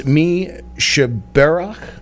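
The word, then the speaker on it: Mi Shebeirach: And for the musical portion of today's program, we will play, and I'm going to ask Rabbi Kerlin to help us out with the pronunciation on this one. It's Mi Shabarach. – You did a great job.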